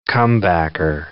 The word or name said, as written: comebacker